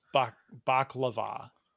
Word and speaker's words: baklava: Baklava or baklava? – So I call it baklava. – Baklava. Oh, wow. Emphasis on the var there.